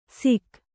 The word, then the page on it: ಸಿಖ್: sikh